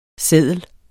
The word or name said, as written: seddel